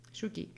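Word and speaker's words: Sookie: Αυτή η Σούκε. – Σούκι. – Σούκι, τέλος πάντων.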